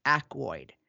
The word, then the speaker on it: Aykroyd: Yeah, on this watch, and I know, you said the other actor as a mispronounced. It's Aykroyd.